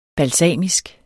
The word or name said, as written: balsamisk